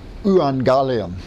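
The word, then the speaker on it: euangelion: Upsilon together, and I pronounce it like this. Evangelion. Evangelion.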